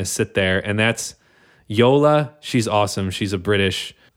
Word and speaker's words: Yola: It's spelled like Lola with a Y. – Yeah, Y-O-L-A.